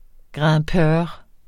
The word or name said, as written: grimpeur